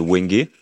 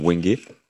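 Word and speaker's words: wengé: Et là, je suis en train d'expérimenter un, bois, le wenge, justement. Alors, pour la prononciation, c'est un wenge. Wenge, d'accord.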